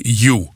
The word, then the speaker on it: U: Het thema is dit jaar United by You. De U is een U van Utrecht, uitgesproken op zijn Engels.